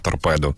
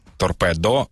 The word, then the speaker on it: торпедо: я кажу поставити на торпеду, але правильний наголос у цьому слові торпедо, і відповідно не відміняється, та поставити на торпедо.